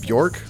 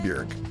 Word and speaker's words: Björk: Why do I say it like that instead of Bjork, like most Americans? Because I learned recently that it's pronounced Bjork. It like rhymes with jerk, not Bjork rhymes with pork, which is how I've said it, I don't know, until last year or something.